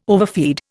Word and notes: overfeed: Indian

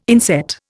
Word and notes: inset: Indian

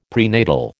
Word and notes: prenatal: American English

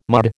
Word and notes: mud: American English

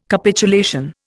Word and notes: Capitulation: Indian

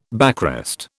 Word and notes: backrest: British English

